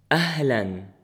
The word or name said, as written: أهلًا